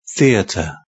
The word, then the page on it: theatre: British English